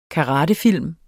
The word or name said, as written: karatefilm